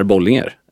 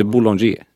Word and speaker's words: Bollinger: Medan Bollinger... är Bollinger. Man säger inte Boulanger i Sverige.